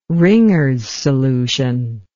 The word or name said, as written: Ringer's solution